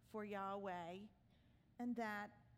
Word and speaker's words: Yahweh: So all this is wrapped up in the letters YHWH, which we pronounce Yahweh.